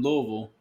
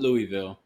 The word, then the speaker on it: Louisville: From Louisville, not Louisville. I heard somebody say Louisville the other day in an interview, and I almost threw the fuck up. I'm like, nope, that's not how you say it.